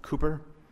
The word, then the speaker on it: Cowper: Lee, George Whitfield, Hudson Taylor, William Tyndale, Adoniram Judson, Jim Elliot, Nate Saint, John Wycliffe, John the Baptist, William Carey, William Cowper, pronounced Cooper, John Newton, George Mueller, Amy Carmichael, Mary Slessor.